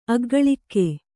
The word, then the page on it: ಅಗ್ಗಳಿಕೆ: aggaḷike